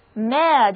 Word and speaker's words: mayor: 想想看，一座城市里最大的人物恐怕就是市长了，所以 Mayor 的意思就是市长。注意字母组合 A-Y-O-R 的读音念作 Air。